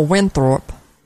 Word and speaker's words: Winthrop: Is that how you pronounce it? Winthrop? – Oh, Winthrop. – Winthrop. – Yeah. – Oh, that's like Trading Places, Winthrop.